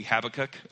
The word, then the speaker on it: Habakkuk: In chapter 3, I've always pronounced it Habakkuk. I know that it's probably Habakkuk, okay, but we're, you know, that's the way Mrs. Doss taught me, so that's what I'm going to say.